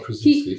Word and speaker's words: Krasinski: So I think I would say John Krasinski, am I saying that right? – Yeah, John Krasinski.